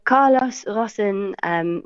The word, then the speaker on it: Carlos Ghosn: Yeah, so Carlos Ghosn, which is how you pronounce his name in Arabic, is a Lebanese citizen.